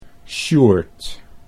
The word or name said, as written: Sjoerd